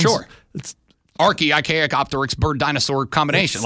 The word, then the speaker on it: Archaeopteryx: It's Archaeopteryx.